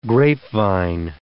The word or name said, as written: grapevine